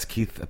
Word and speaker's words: Keith: That's Keith Perron, as they say in French, Perron, oui, oui, oui, oui.